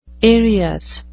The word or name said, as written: aerious